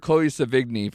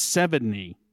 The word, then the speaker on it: Sevigny: I've been calling her Chloe Savigny for years. How would you guys pronounce it? – Uh, I believe it's, should I say it? Because I think I, I know it. – It's Sevigny. – Yeah, it's 70? like 70. Yeah.